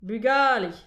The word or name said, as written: bugale